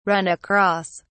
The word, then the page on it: run across: British English